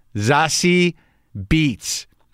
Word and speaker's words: Zazie Beetz: I'm going to pronounce this correctly a few times, I believe. Today, I'm talking to Zazie Beetz, okay? Zazie Beetz.